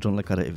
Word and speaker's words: John le Carré: Wszyscy wymawiają to John Le Carré i powinno się John Le Carré wymawiać.